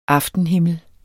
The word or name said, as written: aftenhimmel